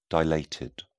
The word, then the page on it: dilated: British English